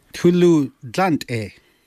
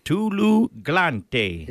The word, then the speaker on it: Tulu Dlante: How do you say, how's the road? – Tulu Dlante. – Tulu Glante. – Yeah, that's perfect. Tulu Glante.